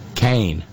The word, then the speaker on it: Kean: I know I'm mispronouncing it, but I'm forgivable for the fact that it's spelled K-E-A-N. Tom Kane. Sorry. Or however it is I'm getting it wrong. Kane.